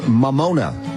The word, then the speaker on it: Mamona: She's got a special out right now on Hulu called Mamona. Did I say that correctly? – Yes, yeah, you nailed it.